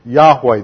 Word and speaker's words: Yahweh: There are only the four letters, the Tetragrammaton, and it's transliterated YHWH. And we supply the vowels, and we're fairly confident these are what they are, and it should be translated or pronounced Yahweh.